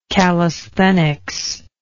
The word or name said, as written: calisthenical